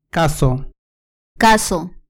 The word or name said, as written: cazo